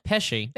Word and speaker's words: Pesci: Pesci.